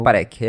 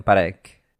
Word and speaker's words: Parekh: Como que você pronuncia isso? Kevin Parekh? – Eu diria Kevin Parekh. – Parekh. Ele é indiano, né? Então deve ser Kevin Parekh.